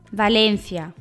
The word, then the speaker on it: Valencia: Sí, pero los españoles lo pronuncian Valencia. Repito, Valencia. con C.